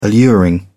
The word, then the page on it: alluring: British English